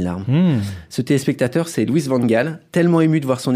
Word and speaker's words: Van Gaal: Louis Van Gaal, il faut dire Louis Van Gaal.